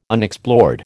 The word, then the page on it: Unexplored: American English